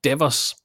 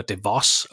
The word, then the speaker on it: DeVos: Firmaet overtog Orlando Magic i 1991 og er grundlagt af Daniel Devers far, Richard Devers, eller DeVos, afhængig af hvordan man lige vil udtale det, var med til at stifte firmaet Amway.